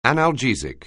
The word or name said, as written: analgesic